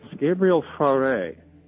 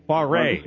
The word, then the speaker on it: Fauré: Gabriel Fauré. – Fauré, okay.